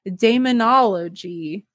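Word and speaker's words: Daemonologie: And he even wrote a book about witchcraft called the Daemonology. That's how you say it.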